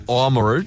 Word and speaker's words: Oamaru: Or as it's pronounced, Oamaru down there.